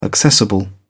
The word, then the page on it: accessible: British English